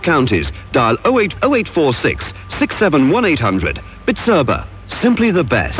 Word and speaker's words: Bizerba: B-I-Z-E-R-B-A. Pronounced Bitzerba.